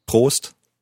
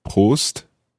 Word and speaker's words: Prost: You can say Prost or Zum Wohl. So let's hear the first one. Prost. Prost. Prost.